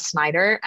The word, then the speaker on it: Snyder: My handle is AKA_Christina Snyder, and it's spelled last name is SNYDER.